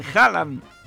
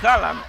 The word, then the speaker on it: Haaland: Y aquí les vamos a contar cómo se pronuncia bien el apellido del delantero estrella de Noruega. – Haaland. Y se acabó. Haaland. – Haaland.